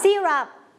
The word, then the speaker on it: syrup: เด็กๆชอบอ่านยาประเภทนี้ว่ายาอะไรไซรัปใช่ไหมคะแต่ทางภาษาอังกฤษเราอ่านว่า syrup